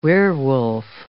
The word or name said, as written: werewolf